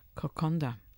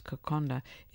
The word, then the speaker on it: kokoda: Kokonda, not the trail, the salad. Not to be confused with the famous Kokoda trail in Papua New Guinea, Kokonda, or it's written as Kokoda but pronounced as Kokonda, is the closest Fiji has to a unique national dish.